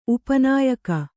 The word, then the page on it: ಉಪನಾಯಕ: upa nāyaka